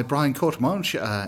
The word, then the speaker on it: Brian Courtemanche: Well, I'm still here at Necronomicon, and I'm joined now by Brian Courtemanche.